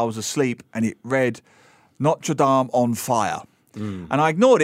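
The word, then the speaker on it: Notre-Dame: And this week, one of the, I think, sadder stories when it comes to history, archaeology, architecture, the burning of Notre Dame. – I noticed you said Notre Dame then. I know Americans say Notre Dame, right? – Well, that's the football team.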